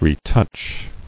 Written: Retouch

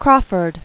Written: Crawford

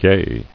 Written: Gayness

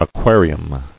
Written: Aquarial

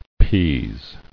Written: Peasen

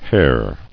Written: Herr

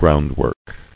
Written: Groundwork

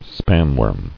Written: Spanworm

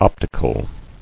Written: Optically

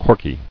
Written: Corkiness

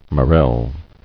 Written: Morel

